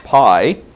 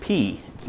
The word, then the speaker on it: pi: Despite the movie that's getting all the buzz for Oscar and everything, Life of Pi, it's pronounced in Greek, P. It's not pronounced pi.